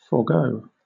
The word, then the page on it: forego: British English